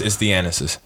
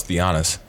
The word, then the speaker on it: Thanasis: And then I think also his brother, Thanasis or Kostas? – Kostas is not on. It's Thanasis. – Thanasis. – Yeah. I thought it was Theonis.